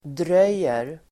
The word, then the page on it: dröjer: dr'öj:er